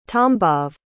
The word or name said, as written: tambov